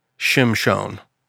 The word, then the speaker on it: Shimshon: And the woman bore a son and called his name Shimshon. That's how you actually pronounce it. – Oh. – But it's Samson. That's how we read it in English.